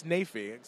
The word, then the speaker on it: Naifeh: Like, I've always been calling you Ted Neff, but it's Naifeh.